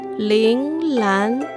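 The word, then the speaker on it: ling lan (铃兰): En chino se dice ling lan, ling lan, ling lan. Y muy interesante, este ling en chino significa campana. Y justamente esta flor se ve muy parecida a una pequeña campana. Ling Lan, lirio de los valles.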